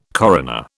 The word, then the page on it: coroner: British English